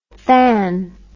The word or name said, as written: tanh